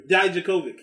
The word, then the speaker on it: Dijakovic: Dominic Dvokovic, or however you say his name. – Dominic Dijakovic. – Yeah. There you go.